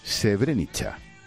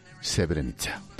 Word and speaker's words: Srebrenica: y me dijo que se dice Srebrenica. Yo lo he dicho mal, se dice Srebrenica.